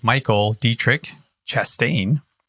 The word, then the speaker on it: Michael Diettrich-Chastain: So today I have a gentleman by the name of Michael Diettrich-Chastain.